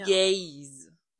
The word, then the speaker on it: Gaze: G-A-Z-E. Für alle, die es jetzt noch nicht verstanden, ja. Gaze. – Gaze. Wird das ausgesprochen? – Ja.